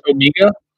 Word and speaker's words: Omega: It's pronounced Omega? – Yes. Even Bro got that wrong last week.